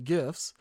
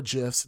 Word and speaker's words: GIFs: So that Ed proceeds to spam us with GIFs or GIFs, depending on how you say it.